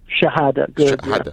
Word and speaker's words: Shahada: So let's start with pronunciation. Is it Shahada? – Shahada. Yeah.